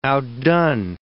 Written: outdone

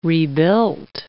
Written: rebuilt

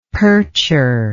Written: percher

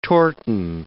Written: torten

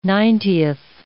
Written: ninetieth

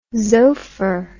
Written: xopher

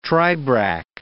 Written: tribrach

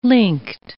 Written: linked

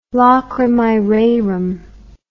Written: lacrimae rerum

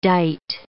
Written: dite

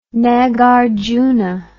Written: nagarjuna

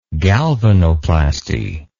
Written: galvanoplasty

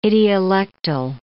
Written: idiolectal

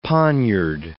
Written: poniard

